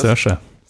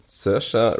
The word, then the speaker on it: Saoirse: Ich weiß nicht, wie spricht man die aus? – Saoirse. – Saoirse Ronan für Little Woman, Women, Charlize Theron für Bombshell und Renee Zellweger für Judy.